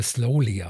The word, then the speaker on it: Saloli: Die schreibt sich Saloli, spricht sich aber Slowly aus.